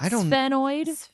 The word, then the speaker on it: sphenoid: What has 22 bones, including the sphenoid, at its base? Did I say that right? Sphenoid? – Sphenoid. – S-P-H-E-N-O-I-D.